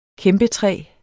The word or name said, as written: kæmpetræ